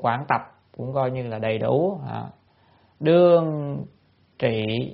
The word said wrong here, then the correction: đương trí: đương trí, cái chuyện này là phải đọc là trí ha.